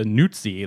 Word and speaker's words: Nuzzi: So one of these interns, and Olivia Nuzzi, that is N-U-Z-Z-I, I'm going with the Italian pronunciation, Nuzzi, possibly Nuzzi, I don't know.